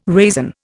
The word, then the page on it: raisin: Indian